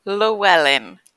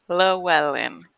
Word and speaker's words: Llewellyn: No. Aisyah Llewellyn. Llewellyn. – Oh, you're Welsh. – Yeah, half Welsh.